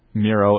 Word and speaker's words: Miro: Miro. M-I-R-O.